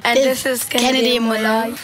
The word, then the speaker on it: Molloy: Pronounce it properly, Dave. It's Molloy. Kennedy Moulton.